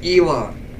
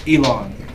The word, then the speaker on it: Elon: I really do think that Cody, as good as a talker he is, can probably bring Elon Musk to our podcast. – Pronounce his name right, you're gonna get us in trouble. It's Elon. – Elon.